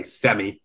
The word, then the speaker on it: Sammy: I say Sammy, you say Sammy.